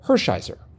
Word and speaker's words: Hershiser: I can't vouch for how he or his family pronounced the family name, but Vin Scully always pronounced Oral Hershiser's name Oral Hershiser.